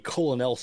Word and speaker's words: colonel: I can't be Colonel Sanders, I have to be Colonel Sanders. – Colonel. – Okay. – Or maybe you actually spell it Colonel. K-E-R-N-E-L.